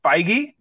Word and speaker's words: Feige: and Kevin Feige.